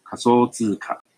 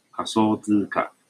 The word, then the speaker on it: 仮想通貨: 首先，今天一直提到的虚拟货币，在这个日语汉字写作假想通货，念作卡索兹卡。那通货呢，就是货币，卡索兹卡。